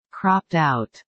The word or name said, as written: cropped out